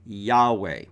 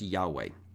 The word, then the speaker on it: Yahweh: And in Hebrew of that time, that was pronounced something like Yahweh. It was four letters in the written language, represented in our English as YHWH. And the Hebrew pronunciation does seem to go as Yahweh.